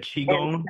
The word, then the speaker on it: Qigong: You said Qigong? – So, yeah. – Did I say it right? – You said it right. – All right, cool. – That was dead on.